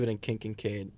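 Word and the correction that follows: Keith Kinkaid: Did I still say that wrong? – Keith Kincaid.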